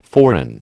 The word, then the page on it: forerun: American English